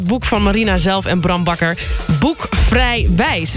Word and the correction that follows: Vrij bewijs: Zeg ik dat goed? – Vrij bewijs. – Vrij bewijs.